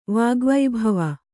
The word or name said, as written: ವಾಗ್ವಿಭವ